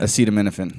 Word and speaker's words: acetaminophen: Acetaminophen.